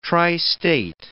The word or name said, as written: tristate